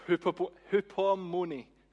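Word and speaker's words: hupomone (ὑπομονή): It's hupomone. Let's go with that. That's how you pronounce it.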